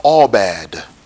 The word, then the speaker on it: abad: The word servant in the Hebrew, it is spelled A-B-A-D. A-B-A-D. It is pronounced Abad. Abad. A-B-A-D. It is pronounced Abad in the Hebrew.